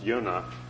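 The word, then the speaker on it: Yonah (יונה): In Hebrew, pronounced Yonah, means dove.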